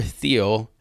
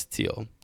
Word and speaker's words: Thiel: Let's go into the Peter Thiel. I think it's Thiel, actually. It's spelled T-H-I-E-L.